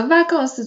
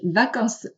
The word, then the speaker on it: vacances: 第二个问题是 vacances，vacances 这个词啊，它只有做复数的时候才可以翻译成假期，否则 vacances 作为单数哈，la vacances 它翻译成空缺。空值只有 pluriel 的时候才会翻译成假期，这个需要大家特别注意一下。但是这个在口语当中体现不出来，因为这个 s 啊是不发音的。好了，这个句子有两个小错误啊，一个是动词我们需要用复数，再一个是 vacances 也需要加 s。